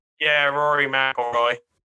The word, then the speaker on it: McIlroy: But if you want legacy, like Rory McIlroy, is it Rory McIlroy? Is that how you pronounce his last name? – Yeah, Rory McIlroy.